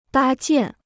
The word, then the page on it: 搭建: dā jiàn